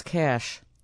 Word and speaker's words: cache: The word is spelled C-A-C-H-E. – This is a harsh. – This is a hard one, because I've heard people split right down the line on this one. – It's cash. – It is cash, K-A-S-H.